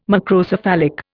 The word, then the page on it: Macrocephalic: Indian